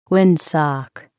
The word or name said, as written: windsock